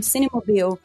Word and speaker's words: Cinemobile: É, mobile de, de, de, Se escreve mobile, né? Mas eles falam Cinemobile.